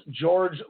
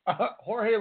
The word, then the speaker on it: Jorge: In his post fight interview, Lundy called for a shot at WBA ring lightweight champion Jorge Linares, which he pronounced George Linares.